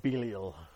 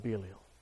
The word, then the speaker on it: Belial: Or to make it more memorable, if you use the Alexander Scorby pronunciation, sons of Belial. Sons of Belial.